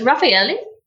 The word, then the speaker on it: Raffaele: Okay, so it's Raffaele, but when people look at it, they, and especially with Australian accents,